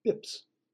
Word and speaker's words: bps: Basis point is often abbreviated as BP or BPS and pronounced BIPS.